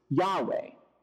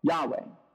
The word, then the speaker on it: Yahweh: How do you pronounce God's name? The best guess that scholars have is Yahweh. Yahweh.